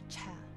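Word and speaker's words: çay: 那在土耳其语中呢，这个茶的发音就是 cha。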